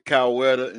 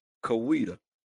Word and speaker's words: Coweta: and it is pronounced different ways. My mom is from that town. – Okay. – And you said Coweta, and some people say Coweta. So it just depends on how you want to say it.